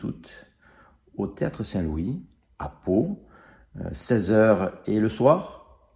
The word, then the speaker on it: Pau: Alors, attention, il y a un jeu de mots, ça s'écrit P-A-U, comme la ville.